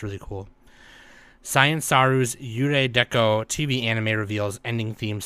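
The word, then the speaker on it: Yurei Deco: Zero is pronounced like Ray in Japanese, Yurei Deko.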